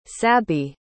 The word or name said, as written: sabby